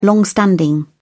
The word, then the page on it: long standing: British English